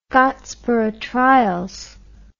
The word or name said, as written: Scottsboro Trials